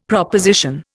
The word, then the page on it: proposition: Indian